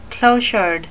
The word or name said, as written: clochard